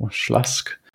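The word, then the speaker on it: Śląsk: So Legia lost 4-0 to, I think it's pronounced, Slask.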